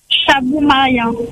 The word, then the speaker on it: Chabou Maria: Chabou Maria. – Ça s'écrit comment? – C-H-A. – Oui. – B-O-U. – Oui. – Espace M-A-R-I-A.